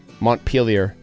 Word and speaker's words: Montpelier: Montpelier.